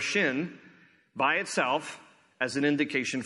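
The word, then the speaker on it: shin (שׁ): If there's a dot over the left branch, it is pronounced sin. If it is over the right branch, it is pronounced shin.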